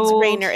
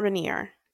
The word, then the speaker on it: Rayner: It's Rainer. It is not Rainier. – It is. – Okay. – It's spelled R-A-Y-N-E-R, but it's, yeah, sure, whatever.